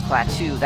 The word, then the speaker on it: Klaatu: My name is Klaatu. That is spelled K-L-A-A-T-U.